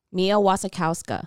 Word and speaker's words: Mia Wasikowska: Mia Wasikowska.